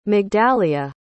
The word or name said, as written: migdalia